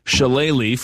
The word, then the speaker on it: shillelagh: I think I could practice spelling shillelagh for the next decade. And I wouldn't get it right. But am I at least pronouncing it correctly? – You're pronouncing it perfectly.